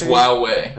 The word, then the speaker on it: Huawei: It's Huawei. Huawei. – Huawei. It's a silent H.